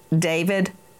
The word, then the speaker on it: D4vd: What a coinkydink that he, David, some people look at his name and it looks like D4VD, and he pronounces it David.